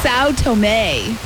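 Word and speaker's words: São Tomé: Sao Tome. Is how you pronounce that.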